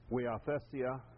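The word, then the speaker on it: huiothesia: The word that we're looking at here, weathesia, is, I'll just spell it in English, it's H-I-O-T, getting right, right in English, T-H-E-S-I-A, weathesia. That's a T. In Greek, you don't have an H. It's just a rough breathing sound, and you translate an H.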